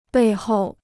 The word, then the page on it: 背后: bèi hòu